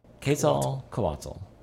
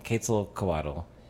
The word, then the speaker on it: Quetzalcoatl: Quetzalcoatl. – Is that right? – Yeah. – Close enough. Way better than I had it.